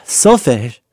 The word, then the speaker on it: solfège: One is, how I'm going to pronounce this, S-O-L-F-E-G-E with an accent, solfège? – Sure. – Solfège, because like in Spanish that means stress. – Mm. – I think this changes the tilt of the pronunciation.